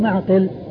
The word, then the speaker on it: معقل: من سواه في صحيح البخاري بهذه الصورة فهو معقل معقل بالعين والقاف بالعين الساكنة والقاف المكسورة. ومغفل